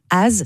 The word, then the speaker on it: As: Az,